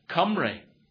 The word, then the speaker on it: Cymru: The name that they use is Cymru, spelled with a C-Y, but Y is really U in Welsh. It gets complicated, but Cymru, and that means friends.